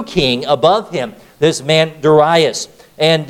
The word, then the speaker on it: Darius: Where before was Nebuchadnezzar, now we see a man by the name of Darius, or some pronounce his name Darius.